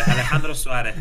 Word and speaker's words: Alejandro Suarez: Alejandro Suarez. I mean, it's very difficult to pronunciate the R because it's not the same in other countries, yes.